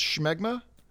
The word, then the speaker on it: Smegma: It's spelled Smegma, but it's pronounced Schmegma.